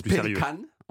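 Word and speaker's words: Pelikan: Ah oui, ça se prononce Pélican.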